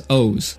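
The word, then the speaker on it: Oz (עֹז): The Hebrew word for strength in this verse's original context is Oz, O Z E. That's how you would pronounce it.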